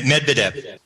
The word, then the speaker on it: Medvedev: How do you say his name? Med. Med, Med Medvedev.